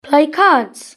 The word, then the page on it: play cards: British English